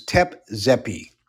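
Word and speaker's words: Tep Zepi: Ancient Egyptian legends tell of, I'm going to spell it and pronounce it, T-E-P, second word, Z-E-P-I. That's known as Tep-Zepi, or that stands for, translated, quote, first time, two words.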